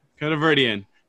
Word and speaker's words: Khodaverdian: Khodaverdian.